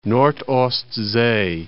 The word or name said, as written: Nord-Ostsee